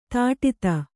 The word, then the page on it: ತಡತ: taḍata